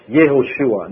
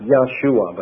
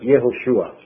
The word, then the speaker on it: Yehoshua: In Hebrew you cannot say Yahovah or Yahweh. That's grammatically an error. Yah comes only at the end, second syllable, but never is the first. And so it's Yehovah, it's Yehoshua, not Yahshua, but Yehoshua. The Yah, the Ah sound changes to Yeh.